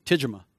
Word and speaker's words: Tijima: When the chief asked how to pronounce my name, and I said, it's easy, Tijima. And he goes, and what is that? And I go, well, it becomes easier if you think like Japanese.